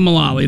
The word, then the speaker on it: Mullally: Malali? How do you say her name? – Megan Mullally or Malani? – Malali. Malali. – Okay, Megan Mullally.